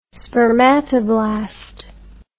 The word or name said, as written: spermatoblast